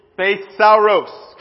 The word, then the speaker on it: thesauros: Now, it's pronounced, the word treasure in Greek, thesauros. Can we all say that together? Thesauros.